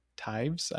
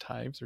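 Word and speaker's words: Toews: I think it's pronounced Tives. I looked up the pronunciation. It's like a Welsh last name. It's T-O-E-W-S, but I believe it's pronounced Tives or Taves.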